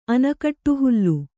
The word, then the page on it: ಅನಕಟ್ಟುಹುಲ್ಲು: kannada